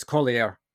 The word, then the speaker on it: Collier: He's another one that I keep mispronouncing, because he even had a told me on the show that it's not, don't speak it like it's French. It's, you know, it's Collier or something like that, not Collier, as I have been calling him.